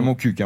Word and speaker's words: Montcuq: Et oui, on dit bien Montcuq, hein, on le rappelle. – On dit Montcuq. – Oui, on prononce bien On dit Montcuq, mais Saint-Cyr-la-Popie et Montcuq. – Il y a des culs qui se prononcent et des culs qui se prononcent pas, quoi.